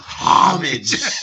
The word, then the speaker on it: homage: Yeah, and I think he would pronounce the H, you know, homage.